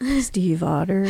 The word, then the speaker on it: Stiefvater: Steve, like the name. Otter, like the animal. Steve Otter.